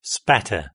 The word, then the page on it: spatter: British English